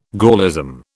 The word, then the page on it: Gaullism: British English